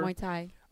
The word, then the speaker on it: Muay Thai: Vicky has also done, I always thought I say this wrong, Muay Thai, whatever. – Muay Thai.